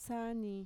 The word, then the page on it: saani: Mixtec